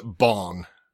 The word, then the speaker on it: Bang: They pronounce it Bong. – Yeah. – But it's spelt Bang.